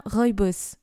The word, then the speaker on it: rooibos: oui, oui, c'est bien rooibos, nous sommes euh, d'accord.